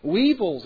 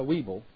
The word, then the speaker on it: weevil: Weevils is one of those things. Am I saying that right? It's a weevil, not a weeble.